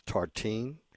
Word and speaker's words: tartine: He said his favorite was tartine. Is that how you pronounce it? – Yeah.